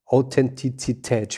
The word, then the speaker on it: Authentizität: Authentizität, schwieriges Wort. – Ja, ja, ja, aber hast ja, schon richtig ausgesprochen.